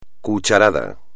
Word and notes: cucharada: espagnol

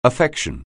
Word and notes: affection: anglais

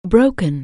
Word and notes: break: anglais

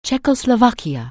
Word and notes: Czechoslovakia: anglais

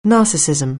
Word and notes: narcissism: anglais

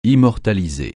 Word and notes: immortaliser: francais

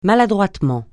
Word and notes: maladroitement: francais

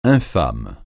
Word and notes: infâme: francais

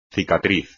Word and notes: cicatriz: espagnol

